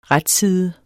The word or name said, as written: RETSIDER